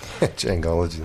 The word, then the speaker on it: Djangology: So Django is the creator of hot jazz, essentially, and known for songs like Minor Swing, Daphne, and Djangology. I believe is how you say it. I'm not too sure. – Djangology, that's right, yeah.